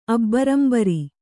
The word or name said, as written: ಅಬ್ಬರಂಬರಿ